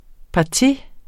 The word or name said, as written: parti